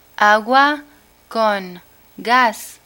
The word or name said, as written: agua con gas